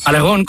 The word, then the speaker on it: Aragorn: De Aragorn, de Aragorn, no de Aragorn. – Aragorn con acento en la O.